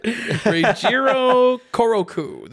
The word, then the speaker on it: Reijiro Koroku: Speaking of that soundtrack, I'm going to butcher his name, but Reijiro Kokuru? – Sounds great to me. – He's not going to try it. Reijiro Koroku. There we go. – Yeah, Koroku.